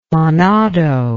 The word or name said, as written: manado